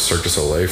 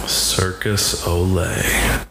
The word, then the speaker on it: Cirque du Soleil: Is that the proper pronunciation? – Yes. – Not instead of what, Cirque de? – Circus Olay. – No, people don't say that.